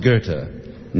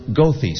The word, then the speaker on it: Goethe: Well, in Germany, it's pronounced Goethe, not in Chicago. It's pronounced Goethe Street. My father remembers a bus driver who was covering all the bases, and he said, Geethy, Goethe, Goethe. Well, he was one-third right.